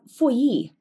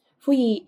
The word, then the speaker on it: Feuilly: But then we have Feuilly, which is a really hard name to say, but when you see a double L in French, you pronounce it like a Y. Feuilly is an orphan who wants to adopt the people.